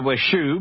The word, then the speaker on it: Shu: For our listener's sake, she spells her first name S-H-U and pronounces it like shoe on your foot, right? – That's right. It's Sue with an H.